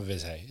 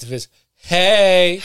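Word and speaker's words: Vizhay: It's not Viz Hay. It's Viz Hay.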